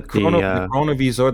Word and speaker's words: Chronovisor: All right, so we're talking about like the Chronos Visor. Is that how you say it? Is it Chronos Visor? – The Chronovisor, the Chronovisor.